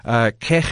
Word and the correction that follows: Chegg: So I love your pronunciation. That is a South Africanism if there ever was one. we do pronounce the J as a K, but it's Chegg.